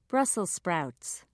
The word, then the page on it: brussels sprouts: British English